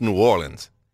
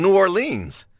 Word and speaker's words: New Orleans: But when you look at the word lean and bean and mean, you know, seen, like all those are spelled like New Orleans. So I think it is pronounced New Orleans. – No, I always say New Orleans, but it, I guess technically. – Like think about team. – New Orleans, not New Orleans. There's an extra syllable.